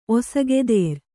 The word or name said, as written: ಒಸಗೆದೇರ್